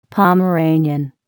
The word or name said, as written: Pomeranian